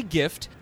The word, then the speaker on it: GIF: That's going to be endlessly gift. People online are going to love it. – Now, I actually heard recently it's actually pronounced GIF, like the peanut butter.